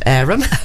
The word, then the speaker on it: Evesham: And again, you can get in touch with the theatre through, if you do a Google search, on Robin Hood Theatre, Evesham, pronounced Arum.